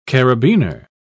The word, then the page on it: carabiner: British English